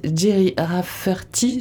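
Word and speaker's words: Gerry Rafferty: vous avez choisi Jerry Rafferty, je prononce bien C'est ça.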